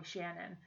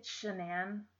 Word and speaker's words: Shanann: I was just like, oh, it's probably Shannon. It's Shanann, and I mispronounced that.